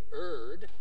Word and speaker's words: erred: that you were going to hear about how later they erred, that's how you pronounce that word,